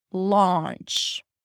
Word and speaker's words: launch: It's not ow, lao, because then it's going to sound like lounge. Let's chill in the lounge. Launch. We're launching our product today, and we're planning a nice lunch. Launch, lunch.